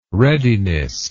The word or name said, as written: readiness